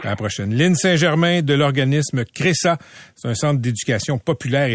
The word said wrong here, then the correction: CRECA: Je l'ai bien prononcé, merci. CRESA? – Non, c'est CRECA.